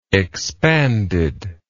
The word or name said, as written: expandedness